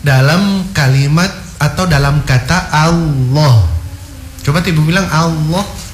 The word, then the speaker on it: Allah: Allah. – Yes. Ya, jadi A itu tipis, lohnya itu yang tebal karena huruf lamnya yang ditebalkan. Fakhimil lama min ismillah ya. Jadi cara bacanya Allah.